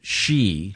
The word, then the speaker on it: Xi: They spell it XI. – Yeah. – But it's pronounced, this says it's pronounced she.